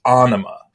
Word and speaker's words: Ænima: And he says that it should be pronounced Ænima.